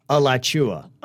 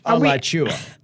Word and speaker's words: Alachua: Somebody said, haha, that's actually still wrong. The pronunciation they gave you, it's Alachua. Alachua. – Oh my gosh. – Yeah. Alachua.